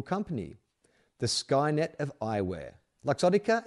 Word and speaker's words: Luxottica: Oh, like luxury. Luxottica? Would it be Luxottica or Luxottica? Luxury. L-U-X. Luxottica. Let's call it Luxottica.